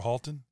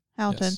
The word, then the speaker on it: Houghton: Houghton, is that how you say it? – Yes, probably. – Houghton or Halton? – Houghton.